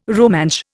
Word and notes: Rumansh: Indian

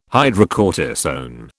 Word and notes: hydrocortisone: British English

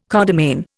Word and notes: codomain: Indian

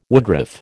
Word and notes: woodruff: American English

